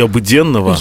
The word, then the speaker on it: Обыденного: Вера и люди» вспоминаем протоиерея Александра Егорова, человека, который почти 50 лет прослужил в московском храме Ильи Обыденного, или, как мы сегодня узнали, от Ксении, правильно говорить, Ильи Обыденного.